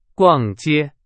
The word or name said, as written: 逛